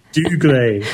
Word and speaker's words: Dougray: He might be Scottish, but Dougray. Say Dougray. Scottish. Go on. – Dougray Scott.